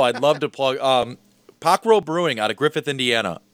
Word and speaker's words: Pokro: It is called Pockrell Brewing. – Pockrell. – Spell it, please. – P-O-K-R-O.